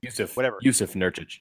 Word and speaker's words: Jusuf Nurkić: He will be the backup center because Jusuf Nurkić, how do you pronounce his name? I can't pronounce his name. – Jusuf Nurkić. – Jusuf. – There you go.